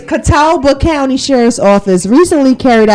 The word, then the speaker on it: Catawba: Catawba? – Where is that at? – Catawba County. Catawba.